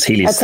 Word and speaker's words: Helius: I just realized I said the company name wrong, so I apologize. It's Helius Therapeutics.